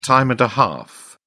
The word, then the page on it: time and a half: British English